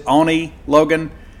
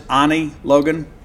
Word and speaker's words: Oni Logan: So nevertheless, Lynch Mob has a handful of albums with, he pronounces it Oni Logan. We pronounce it Oni Logan.